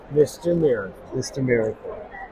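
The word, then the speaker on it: Mister Miracle: Like a Mr. Miracle. – Yeah. – Did I say it right? – Yeah. Mr. Miracle.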